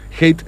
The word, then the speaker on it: Heit: Hate se escribe H-E-I-T, donde están organizando un sorteo con la gente de una importante pinturería de nuestra ciudad, Chino.